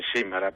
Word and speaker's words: Simala: Simala, perfetto, ho detto anche l'accento giusto.